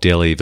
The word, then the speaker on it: Denis: is that I'm a huge fan of Denis Villeneuve. Is that how you pronounce his name? Denis? – Well, we call him Dennis. We're Australians. – Dennis. Like Dennis Lilly.